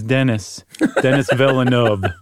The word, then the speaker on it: Denis Villeneuve: I'm sorry, Chuck, I can't not correct you. His name is Denis Villeneuve. I'm sorry. – Okay, I mispronounced it.